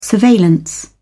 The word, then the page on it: surveillance: British English